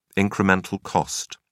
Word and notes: incremental cost: British English

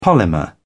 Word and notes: polymer: British English

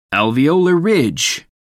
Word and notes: alveolar ridge: American English

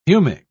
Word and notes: humic: British English

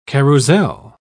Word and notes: carrousel: British English

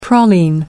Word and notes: praline: American English

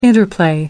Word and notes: interplay: American English

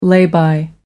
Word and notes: lay-by: American English

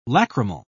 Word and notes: lachrymal: British English

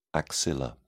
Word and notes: axilla: British English

